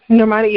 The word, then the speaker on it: Normani: Normani, yeah.